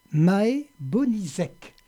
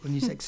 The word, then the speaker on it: Bonizek: Et Maë Bonizek. – C'est ça. – C'est comme ça qu'on prononce votre nom, c'est à peu près. – À peu près. – À peu près, Bonizek.